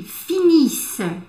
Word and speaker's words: finissent: Ils finissent. Ok Ça va Je finis. Tu finis e fini, No finissons, vous finissez, ils finissent. Finissent. Atenção na pronúncia da terceira pessoa do plural.